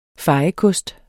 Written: fejekost

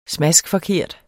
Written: smaskforkert

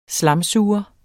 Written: slamsuger

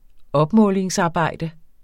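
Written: opmålingsarbejde

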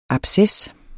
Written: ABSCESSERNES

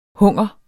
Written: hunger

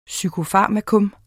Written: PSYKOFARMAKA

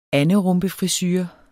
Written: anderumpefrisure

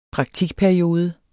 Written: praktikperiode

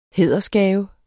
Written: hædersgave

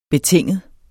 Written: betinget

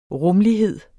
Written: rumlighed

